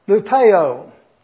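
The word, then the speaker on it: lupeo (λυπέω): The Greek word there is lupeo, L-U-P-E-O. I even put it down here how to pronounce it. L-O-O-P-E-H-O, lupeo.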